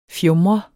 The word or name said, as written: fjumret